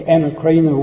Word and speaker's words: anakrino: It's, I think, anakrina would be how you pronounce it, A-N-A-K-R-I-N-O.